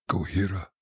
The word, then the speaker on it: Gojira: A couple weeks ago, I kept pronouncing it. – Gojira. – Which I think is how it really is pronounced. – I think it sounds much better. – Gojira. – Yeah. I think it's Gojira.